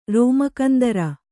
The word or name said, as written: ರೋಮಕಂದರ